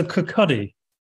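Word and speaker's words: Kirkcaldy: And we were heading to Kirkcuddy because we... – Oh, good pronunciation.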